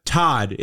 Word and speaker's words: Thot: So, I mean, you got Todd. It's spelled T-H-O-T, but I guess it's Todd in German.